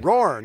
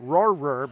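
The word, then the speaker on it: Roher: My last name is Roar, like a lion. – Roar, not Roarer, but Roar.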